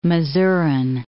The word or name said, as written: Masuren